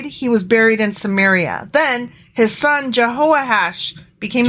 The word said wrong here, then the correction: Jehoash: I apologize. I mispronounced that. It's Jehoash.